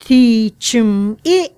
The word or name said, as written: Tthi Chume